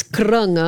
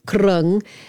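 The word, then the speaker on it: kroeung: Okay, so it's Krung, it's spelled Yes. – Kroeun mostly, pronounced Krung.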